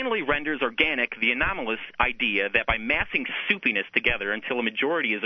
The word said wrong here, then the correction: supineness: You're mispronouncing supineness. Supine, to lay on one's back, essentially to lay down.